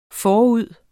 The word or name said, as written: forud